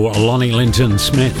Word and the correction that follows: Lonnie Liston Smith: Lonnie Liston Smith. I always say his name wrong.